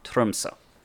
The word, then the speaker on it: Tromsø: To me, it looks like it's just Tromsø, but how do you say it one more time? – Tromsø. We got three extra letters there, so it's an O with a slash through.